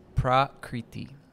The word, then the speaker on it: prakriti: So the second quality is prakriti. Prakriti. And prakriti can be s- broken up into its syllables. Pra, kri, iti.